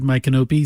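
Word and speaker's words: Micanopy: Now, here's one that's difficult to pronounce, but it's My Canopy, Florida. You got to get the emphasis in the right syllable.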